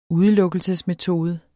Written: udelukkelsesmetode

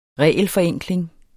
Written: regelforenkling

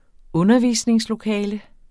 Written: undervisningslokale

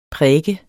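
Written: PRÆKER

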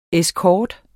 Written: escort

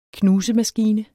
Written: knusemaskine

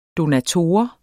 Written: donator

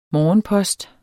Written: morgenpost

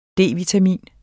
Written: D-vitamin

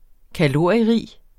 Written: kalorierig

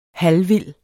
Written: halvvild

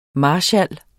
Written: MARSKALS

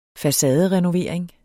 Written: facaderenovering

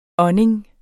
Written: ånding

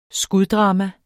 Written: skuddrama

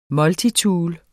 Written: multitool

